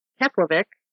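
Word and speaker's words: Keflavík: And left his home, drove to the Harbor Cafe in Keflavik, and vanished into thin air.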